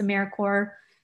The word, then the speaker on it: AmeriCorps: So it's spelled AmeriCorps, but it's AmeriCorps.